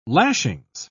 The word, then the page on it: lashings: British English